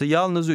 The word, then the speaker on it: yalnız: The Y in the third word is silent. – Fiyatı yalnız 304 lira.